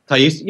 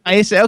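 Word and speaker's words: Taissa: But how do you pronounce Thaisa Farmiga? – Thaisa, yeah, that was good. – Thaisa, okay.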